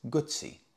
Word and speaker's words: Guzzi: And I explained to him, no, it's not, because two Z's in Italian is pronounced T-S, Guzzi.